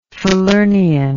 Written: falernian

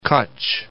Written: Kachchh

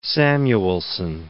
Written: Samuelson

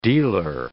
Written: dealer

